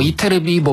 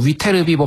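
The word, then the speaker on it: Ytterby: 네, 뭐 이테르비, 뭐 위테르비, 뭐 발음은 뭐 둘 중에 하나인데.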